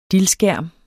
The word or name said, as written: dild